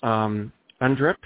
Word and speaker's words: UNDRIP: It's UNDRIP in Vancouver.